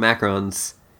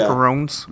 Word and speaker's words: macaron: For Christmas, and she came back with some macarons. I think that's how you pronounce that. I don't care. Macaroons. – Yeah. Macarons. – Macaron. Okay. – It's macaroni. – Macarons and macaroons are two different things.